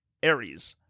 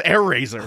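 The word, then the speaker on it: Airazor: Is it pronounced that way? Because it looked like, if it's spelled that way, fine, but pronounced it as Ares. – No, they pronounced it as Air Razor.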